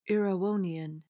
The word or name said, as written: erewhonian